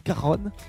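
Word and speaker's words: cajón: Il dit caronne. – Caronne plutôt, ouais. – Qu'on veut, bien le prononcer. Espagnol. ça vient, d'Espagne.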